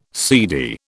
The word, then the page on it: seedy: British English